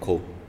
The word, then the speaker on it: Khole: I'm an aspiring rapper, and I go by the name of Cole. – It's spelled K-H-O-L-E, not the Cole from J. Cole.